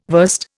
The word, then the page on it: verst: Indian